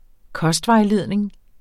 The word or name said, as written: kostvejledning